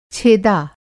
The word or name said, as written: छेदा